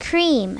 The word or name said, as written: cream